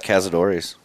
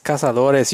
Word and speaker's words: Cazadores: Grab that Cazadores. – What? It's Cazadores. You can't even say it right.